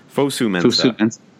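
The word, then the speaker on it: Fosu-Mensah: Fosu Mensah.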